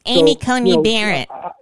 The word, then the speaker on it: Amy Coney Barrett: It's Amy Coney Barrett. You got to say it correctly.